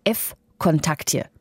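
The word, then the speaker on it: F-Kontakte (ВКонтакте): Der heißt F-Kontakte. Das schreibt sich V-Kontakte, spricht sich aber F-Kontakte.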